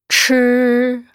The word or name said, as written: chi